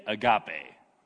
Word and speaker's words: agape: Say agape. Agape. There's like an accent on the E at the end. It's spelled A-G-A-P-E. And is the most commonly used word for love in all of the Bible, especially the New Testament.